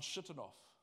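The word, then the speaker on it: Shigionoth: A prayer of Habakkuk the prophet on Shittanoff. It's how you pronounce it. Don't believe me, look it up on Google.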